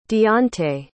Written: deante

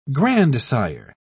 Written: grandsire

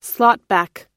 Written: slotback

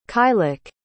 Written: caillic

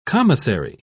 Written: commissary

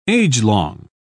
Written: agelong

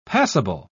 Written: passable

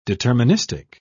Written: deterministic

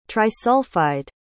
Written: trisulfide

